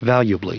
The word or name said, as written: valuably